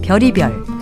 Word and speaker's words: 별의별: 발음은 별이별 또는 별의별이라고 합니다. 간혹 별아별이라고 하는 분도 있던데요. 이것은 맞는 표현도 아니고 맞는 발음도 아닙니다.